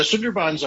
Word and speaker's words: Sundarbans: Sundarbans? – Yeah, the Sundarbans are a globally unique ecosystem, the largest mangrove tidal estuary in the world.